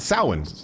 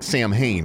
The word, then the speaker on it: Samhain: It's Halloween's origins date back to the ancient Celtic festival of Samhain. Looks like Samhain, but it's actually pronounced Samhain.